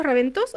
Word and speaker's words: Raventós: Bien, ¿pronuncié bien el apellido Raventós o Raventós? – Es la primera vez que lo pronuncian bien, Raventós, con acento en la O, sí.